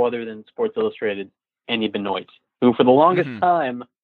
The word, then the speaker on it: Benoit: But apparently this guy, he is the one person whose name isn't pronounced the proper way. It's Benoit. Mm-hmm.